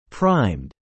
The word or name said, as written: primed